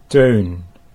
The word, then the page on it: Teun: Dutch